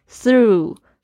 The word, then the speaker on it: through: The best way to learn，最好的学习方式就是 is through speaking，要注意一下你们的 through 的发音， th 这个发音不是很好发，它是轻轻咬住舌尖发出了这个气音 through。它的这个 gh 这个字呢是没有发音的。